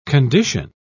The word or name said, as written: condition